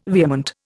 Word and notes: vehement: Indian

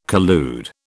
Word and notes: collude: British English

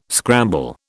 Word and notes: scramble: British English

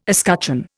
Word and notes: escutcheon: Indian

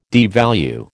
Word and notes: devalue: American English

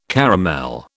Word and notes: caramel: British English

